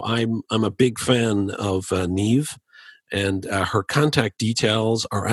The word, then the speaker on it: Niamh: And if you want to get in touch with her, it's Neve. Neve is spelled N-I-A-M-H, and last name Paul, P-A-U-L.